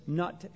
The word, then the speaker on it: Nut: His consort, his wife, and also sister, strangely enough, was the sky goddess, Nut, N-U-T, or Nut, as they apparently pronounce it.